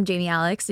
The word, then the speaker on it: Jami Alix: I am Jamie Alix. So it's at Jamie Alix, but it's spelled really weird because I think my parents were like, we're going to give you a name. J-A-M-I-A-L-I-X.